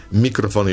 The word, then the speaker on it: mikrofon: Mikrofon i mikrofon, magnetofon i magnetofon,